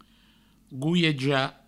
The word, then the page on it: goiejar: gouieja